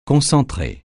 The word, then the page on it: concentré: francais